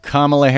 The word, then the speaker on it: Kamala: Kamala Harris, that's how her name is pronounced.